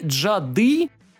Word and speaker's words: джады: и джады, ударение на последний слог, ведьма.